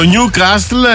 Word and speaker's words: Newcastle: Το Νιου Κάστλ, ελληνιστή νεό καστρό, κατ' επέκταση και η ομάδα. Προφέρεται Νιου Κάστλ. – Δεν έχει τάφ, έχει δίκιο. – Ναι, έχει δίκιο, Νιου Κάστλ. Άνευ του.